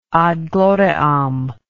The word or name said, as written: ad gloriam